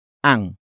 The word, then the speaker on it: ang: It is answered by the next phrase, starting with ang, which is spelled as A-N-G.